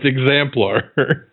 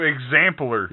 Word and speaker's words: exemplar: It's exemplar. – No, exampler. – You're saying it wrong. Say it exemplar.